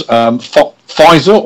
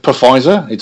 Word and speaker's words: Pfizer: Talking of sort of on the same theme, vaccinations, if you follow the news, Pfizer, or is it Pfizer? There's a silent P there somewhere.